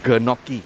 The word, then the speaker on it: gnocchi: Abacus seed is, I'll give you an Ang Mo name, yam version of gnocchi. – I see. – Got okay. – It. – Or to people who cannot pronounce GN, it's gnocchi.